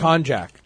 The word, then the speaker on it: Konjac: It was C-O-N-J-U-C, I think. – Konjac. Konjac fiber, which is actually glucomannan fiber. It was Dr. J-A-C.